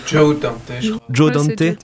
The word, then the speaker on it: Joe Dante: Car Martin Scorsese, Peter Bogdanovich et Joey Dante, je sais pas comment on prononce ça. – Joe Dante.